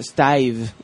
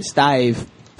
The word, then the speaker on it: Stayve: He's going to have to pronounce that for me because it's like S-T-A-Y-V-E. – Yeah, Stave. That's how you're supposed to say it. Stave.